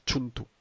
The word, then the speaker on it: Cthulhu: Soylo, pero la pronunciación correcta según el autor es Realms of Chuntu.